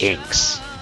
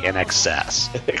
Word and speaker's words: INXS: You know, for the longest time, uh, before I knew how to pronounce the band, I thought the band's name was Inks until I realized no, it's actually INXS.